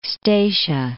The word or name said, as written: Statia